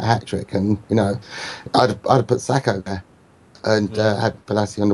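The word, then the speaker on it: Sako: If Zaha's got it on the other wing, there's three players, and the same with Sarko. – Sacco. – I didn't say it right then.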